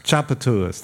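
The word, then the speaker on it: Tchoupitoulas: So when you mentioned your second film, that's I always have trouble pronouncing Chapatoulas. – Chapatoulas, thank you.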